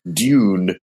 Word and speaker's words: Dune: We have a diphthong there. I would say Dune. – Dune. Okay. Dune. – Yeah. It's like June, you see.